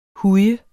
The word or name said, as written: huj